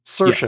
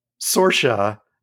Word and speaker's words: Saoirse: Her monologue was how to pronounce her name, Saoirse. Yes. Not Saoirse, not Soiree, but she did it to the tune of Liza with a Z, which is why I recognize the tune.